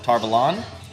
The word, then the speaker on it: Tar Valon: but now the show pronounces it Tarvalon.